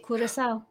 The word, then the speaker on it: Curaçao: and I'm going to see if I can say this properly, Curaçao. – Yeah, that's right, Curaçao.